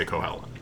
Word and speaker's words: Cuchulainn: I think there would be different ways of pronouncing it, but people today say Cuchulainn.